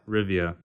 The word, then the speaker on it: Rivia: Rivia. – Okay, Geralt of Rivia.